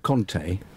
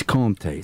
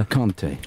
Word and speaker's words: Kanté: It's not Kanté. – Right. – It's Kanté. It's a different... It's like Tour and... Tour is a slight difference just So say his name for me, kiddo. – Kanté. – N'Golo Kanté.